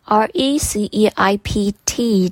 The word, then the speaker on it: receipt: 记得 receipt, R E C E I P T 的 P 不发音，是念 receipt。